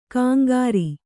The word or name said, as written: ಕಂಕರಿ